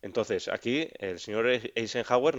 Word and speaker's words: Eisenhower: Y en cuanto a las tareas, a la hora de clasificarlas, pues bueno, aquí el señor Eisenhower, ¿lo he dicho bien? – Sí, señor.